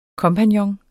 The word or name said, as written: kompagnon